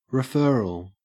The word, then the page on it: referral: Australian English